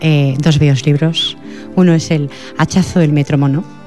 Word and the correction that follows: metrónomo: ¿Lo digo bien? ¿Metrónomo? Metrónomo. Metrónomo, ¿verdad?